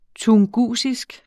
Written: tungusisk

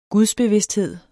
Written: gudsbevidsthed

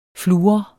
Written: fluor

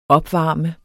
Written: opvarme

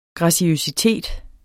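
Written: graciøsitet

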